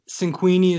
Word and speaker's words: Cinquini: Of course, uh, so Cinquini is spelled C I N Q U I N I, and then Jesse is J E S S E.